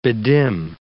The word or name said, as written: bedim